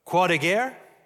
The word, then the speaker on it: Croix de Guerre: I said this incorrectly at the first service, but the Croix de Guerre, the Cross of War, which is a French award.